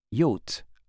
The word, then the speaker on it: yacht: Yacht, prononcé yacht.